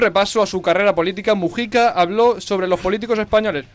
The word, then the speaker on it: Mujica: Rápidamente, el pasado domingo, el programa Salvados volvió a traer a nuestras pantallas a José Mujica, que os pido asesoramiento para pronunciar Mujica o Mújica. – Yo digo Mujica. – Mujica. Pero no tiene tilde. – Ya, pero lo decimos mal.